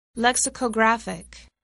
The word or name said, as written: lexicographic